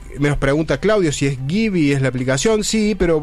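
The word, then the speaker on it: Giby: Se escribe G de Guillermo, I de Indio, B corta de Víctor, Y de Yankee. Gibi, Gibi se pronuncia, pero se escribe así, Gibi.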